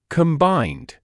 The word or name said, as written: combined